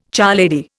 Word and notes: charlady: Indian

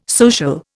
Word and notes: social: Indian

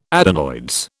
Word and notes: adenoids: British English

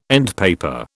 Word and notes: endpaper: British English

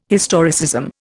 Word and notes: historicism: Indian

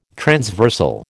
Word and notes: transversal: American English

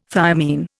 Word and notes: thiamine: Indian

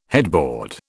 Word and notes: headboard: British English

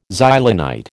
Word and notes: Xylonite: American English

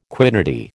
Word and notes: quiddity: American English

